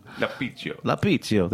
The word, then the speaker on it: La Pizio: La Pizio. I'm probably butchering that pronunciation. – La Pizio. – There, it sounds better like that.